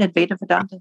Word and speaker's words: Advaita Vedanta: Advaita Vedanta? – Yeah.